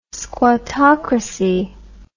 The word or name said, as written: squattocracy